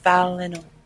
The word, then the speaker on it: Valinor: It's Valinor. – Valinor? – Language of the Valar. – Can you spell that for me, please? – V-A-L-I-N-O-R.